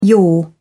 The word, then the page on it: yaw: British English